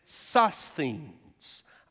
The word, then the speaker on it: Sosthenes: And the way you pronounce that is Sosthenes, our brother Sosthenes,